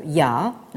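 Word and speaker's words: ya (亚): Et cette spatialisation, cette structuration spatiale, traduit dans des figures qui rappellent ce caractère qui se prononce en chinois moderne, mais peu importe, ya.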